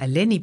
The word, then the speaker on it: Eleni: In de naam van oma Eleni bijvoorbeeld komt twee keer een E voor. Die spreek je allebei net zo uit als de E van melk.